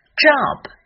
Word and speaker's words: job: Job, J 的发音是 J, J. O 在这里的发音是 O, O. 口腔比较饱满，双唇收圆，下颚放低，舌的前部也要放低，这样音才能够饱满。 O, B 的发音是切记在后面加上变成 b，这就不对了。